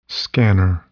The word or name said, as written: scanner